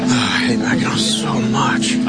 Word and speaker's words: Mackinac: When he goes to Mackinac Island? – Oh, no, no, Mackinac Island. – Mackinac Island. – First of all, yeah. Yeah. – It's spelled, why do they spell it Mackinac when it's Mackinac?